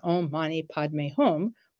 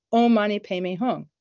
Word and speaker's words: Om Mani Padme Hum: The most famous mantra is the mantra of compassion, Om Mani Padme Hum, or as they pronounce it in Tibet, Om Mani Padme Hum.